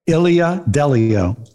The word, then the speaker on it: Ilia Delio: How do you say her name? – Ilia Delio.